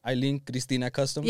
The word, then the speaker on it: Aileen Cristina Customs: Aileen Cristina Custom? – Yeah. – There you go. See, I can say it right.